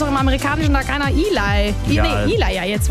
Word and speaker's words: Ely: Also E-L-Y, okay. Weißt du, wie ich das am Anfang ausgesprochen habe? – Nee. – Ellie Oaks. – Ah, nee, Eli.